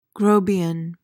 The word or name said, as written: grobian